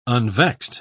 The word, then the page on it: unvexed: British English